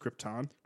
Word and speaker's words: Krypton: Like, was he such an asshole at this point that you couldn't say to him, Mr. Brando, it's pronounced Krypton?